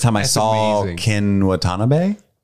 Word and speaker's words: Watanabe: When you finally pronounce his name correctly, he's going to give you a hug very tearfully and say, beautiful. – Watanabe. – There you go.